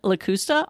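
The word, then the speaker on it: Lakusta: So my Twitter handle is @ N Lacusta, I'll spell that, N-L-A-K-U-S-T-A.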